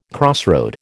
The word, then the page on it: crossroad: American English